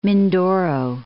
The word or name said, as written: Mindoro